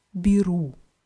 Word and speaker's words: беру: Und dieses je wird aber eher wie i ausgesprochen, weil es unbetont ist. Also ich nehme heißt ja biru.